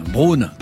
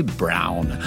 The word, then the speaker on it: Brown: Brown, comme les rasoirs, alors qu'en fait, il faut prononcer Brown.